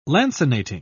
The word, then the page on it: lancinating: British English